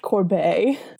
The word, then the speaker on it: Corbeil: It's pronounced Corbet.